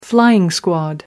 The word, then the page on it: flying squad: American English